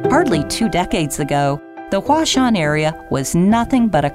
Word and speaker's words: Huashan: The Chinese pronunciation for the Japanese characters used in this name sound like Huashan, and hence the current name for the Creative Park was born.